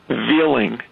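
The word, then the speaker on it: Veling: His last name is spelled V-E-I-L V-I-L-L-I-N-G, Veeling, and they've never pronounced it right.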